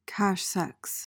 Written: cache-sexe

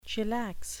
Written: chillax

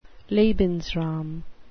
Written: lebensraum

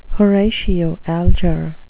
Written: horatio alger

